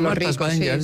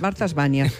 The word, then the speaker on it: Martha's Vineyard: Sí, Martha's Vineyard es una isla donde van todos los ricos. – Ah, Martha's Vineyard. – Lo he dicho mal. Martha's Vineyard, vale.